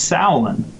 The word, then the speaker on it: Samhain: It's actually written Samhain, but it's pronounced Samhain, and that's an Irish Gaelic term, and that in Gaelic means summer's end.